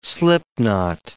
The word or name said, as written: slipknot